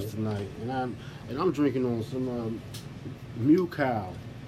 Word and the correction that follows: Macau: Is that how you say it? – Macau.